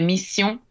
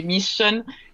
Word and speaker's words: Mission: Sì, allora il titolo è appunto sarebbe francese, quindi si pronuncerebbe Mission. Ma in realtà, essendo una parola che funziona anche in inglese, va bene anche per la vendita internazionale, quindi lo possiamo dire Mission, perché